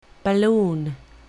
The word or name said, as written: balloon